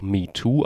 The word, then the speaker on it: MeTwo: Und plötzlich gibt es einen neuen Hashtag, ein Hashtag, der letzte Woche auf Twitter viral ging, der genauso klingt wie der alte Hashtag, nämlich auch MeToo ausgesprochen wird, sich aber anders schreibt, nämlich nicht M-E-T-O-O, sondern M-E-T-W-O, also das englische Zahlenwort 2 ist drinne, heißt aber im Endeffekt auch ich auch.